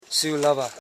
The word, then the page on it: siulaba: si.u.la.ba; Koro